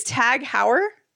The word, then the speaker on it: Tag Heuer: It is Tag Heuer. – I think that's how it's pronounced. – H-A-U-E-R.